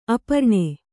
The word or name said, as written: ಅಪರ್ಣ